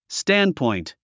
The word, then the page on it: standpoint: stǽndpɔ̀int; スタンドポイント